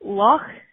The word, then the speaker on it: Loch: And got Loch. So you have to kind of your CH. At the end, so you have Loch Ness and Loch Lomond.